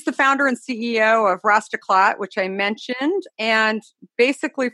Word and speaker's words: Rastaclat: You got it perfectly, Rastaclat.